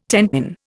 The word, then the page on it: tenpin: Indian